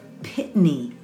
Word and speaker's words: Pitney: And excuse me, I have been mispronouncing his name this whole time. I'm so sorry. It's Philip Pitney. Excuse me. P-I-T-N-E-Y.